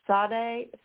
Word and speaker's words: Sade: Kool and the Gang, Oasis, Sinead O'Connor, Sade, Sade, how do you say her name? – Sade. – Sade? – Yeah.